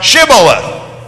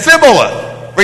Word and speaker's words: Shibboleth: If he said no, then they would say to him, "Say now Shibboleth." But he said Sibboleth. He could not pronounce it correctly.